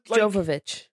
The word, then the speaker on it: Jovovich: Mila Djokovic. I mean, no. – Jovovich. – Jovovich. Jovovich. Oh, it is Jovovich.